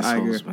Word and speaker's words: Iger: Let's not be Iggs. Iger. It's pronounced Iger.